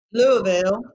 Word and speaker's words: Louisville: So a few weeks after the DS Marketing Summit, I'll be down in Louisville, Kentucky. Did I say it right? – Louisville, yep.